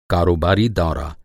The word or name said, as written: कारोबारी